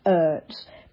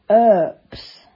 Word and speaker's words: Earps: Well, I've been pronouncing her surname Ertz, but it's actually spelt E-A-R-P-S, Erps.